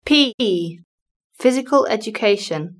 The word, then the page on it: PE physical education: British English